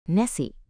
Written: Nessie

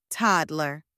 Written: toddler

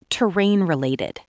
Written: Terrain-related

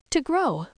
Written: to grow

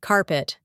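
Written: carpet

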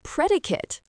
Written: predicate

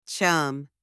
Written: chum

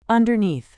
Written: underneath

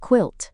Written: quilt

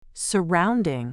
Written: surrounding

